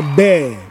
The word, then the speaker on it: bear: But then the animal is pronounced bear.